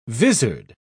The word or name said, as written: vizard